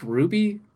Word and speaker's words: RWBY: I think it's just pronounced Ruby, but yeah, RWBY.